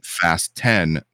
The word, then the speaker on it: Fast X: But it's pronounced Fast 10, not 10.